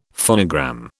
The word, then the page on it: phonogram: British English